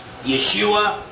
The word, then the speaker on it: Yeshua: Joshua would be pronounced Yeshua in Hebrew.